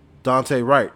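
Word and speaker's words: Daunte Wright: But we do want to say, we do want to give a little rest in peace shout out to Dante Wright.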